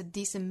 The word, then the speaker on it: decent: It's also the only 连读， the only place where you can get a decent meal, 后面挤出也有辅元连读， get a 后面 decent 尾部的 t 略掉， decent meal, 注意 decent 这个单词的发音哈，第一个音节之中的元音是紧音，不要看到字母 e 就发成 a 的音哈，注意是 decent, 相当于是 s-e-a-t,